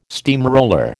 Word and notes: steamroller: American English

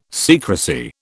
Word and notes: secrecy: British English

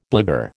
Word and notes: blagueur: American English

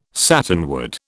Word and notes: satinwood: British English

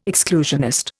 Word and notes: exclusionist: Indian

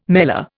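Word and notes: mêlée: Indian

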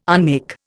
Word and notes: unmake: Indian

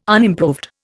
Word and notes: unimproved: Indian

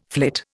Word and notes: flit: Indian

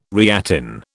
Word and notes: reattain: British English

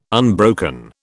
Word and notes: unbroken: British English

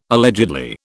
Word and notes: allegedly: British English

